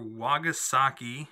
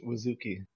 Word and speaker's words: Wazuki: So take to 10 years before the game starts, where Serge and his father Wagasaki, I don't know how to say it. He was attacked. Serge is attacked by this panther demon. He's gravely hurt, and they are I think it's just Wazuki.